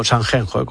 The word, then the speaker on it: Sanxenxo: y te cuento que este fin de semana en Sanxenxo, no Sanxenxo, como dicen. – Qué bien lo pronuncias. – Sanxenxo, es como Shakira.